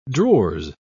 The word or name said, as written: drawers